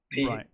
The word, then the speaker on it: Pyun: And it was directed by Albert, I think that you pronounce that Fun. P-Y-U-N, Fun.